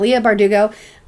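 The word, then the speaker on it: Leigh Bardugo: Like, we're watching Shadow and Bone right now, is based off of the Shadow and Bone trilogy by, I don't know how to say her name. I think it's Leah Bardugo? – Yes, Bardugo. Yeah, you know what? Let's go with that. Leah Bardugo.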